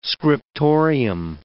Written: scriptorium

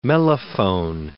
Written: mellophone

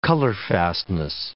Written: colorfastness

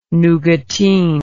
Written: nougatine

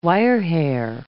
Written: wirehair

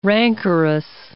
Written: rancorous

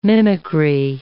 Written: mimicry

